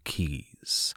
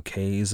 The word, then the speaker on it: Keys: The Dutch called them Kai, and the English anglicized it to Keys. All of which is to say, if I slip up and call them Kays at some point, don't blame me. I'm just following older pronunciations.